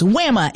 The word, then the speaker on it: WAMA: The WAMA, am I saying that right? W-A-M-A? – The WAMA.